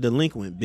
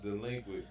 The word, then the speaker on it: DLEQUINT: All right, we need y'all to look in y'all camera and say it's pronounced delinquent, bitch. – All right, it's pronounced delinquent, bitch.